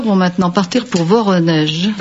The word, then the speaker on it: Voronezh: Voronezh. Je ne sais pas si je prononce bien. – Voronezh.